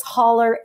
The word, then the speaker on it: Holler: Yeah, Judy, J-U-D-I. I'm Judy with an I. And my last name is Holler, H-O-L-L-E-R, Holler.